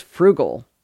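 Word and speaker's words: Froogle: The next Google tool on the list is Frugal, which is an oldie but a goodie.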